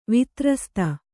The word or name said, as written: ವಿತ್ರಸ್ತ